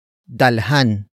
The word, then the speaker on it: dalhan: Another example, dalhan mo ako ng pagkain.